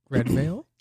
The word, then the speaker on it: Redveil: There's an artist called Red DeVille, R-E-D-V-E-I-L. – Red Veil? – Red Veil. Is it Red Veil? That's how you pronounce it?